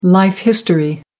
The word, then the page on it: life history: American English